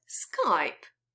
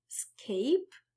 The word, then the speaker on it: Skype: Okay, the fifth and last mistake, very short, is connected with Skype. And again, be careful with pronunciation. Lots of Poles say Skype, like escape, but in fact, the pronunciation is Skype, like hype and wipe.